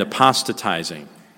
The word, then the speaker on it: apostatizing: So, saints, there's no denying that the Scriptures speak of people falling away and apostatizing. I know it sounds like a weird word, but that's actually the right way to say it, apostatizing.